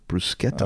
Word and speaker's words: bruschetta: One example CNN gave was in pronouncing bruschetta as bruschetta, not bruschetta, might cost Italians. It's actually bruschetta.